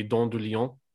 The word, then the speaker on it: dent de lion: If you pronounce it in a French way, it would be dent de lion.